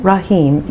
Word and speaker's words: Rahim: Rahim, yes. In the text it's spelled R-A-H-I-M, but you know sometimes the transliteration is E-E-M if it helps you remember yeah.